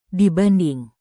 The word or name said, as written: dibanding